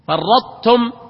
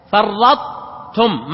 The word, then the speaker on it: فرطتم: لكن لما تقرأ إيش فرطتم، ما تقول فرطتم، لا، إدغام تبقي صفة الطاء وهي التفخيم والاستعلاء. فرطتم يعني تبدأ كأنك تريد أن تنطق طاء وتنتهي بالتاء. لئن بسطت إلي يدك، قال أحطت، ما تقول أحطت، هذا يسمونه إدغام ناقص، وهذا كله من المتجانسين.